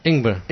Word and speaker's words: Ingber: But how do you say that? – Ingber. – Ingber is ginger? – Ingber is ginger.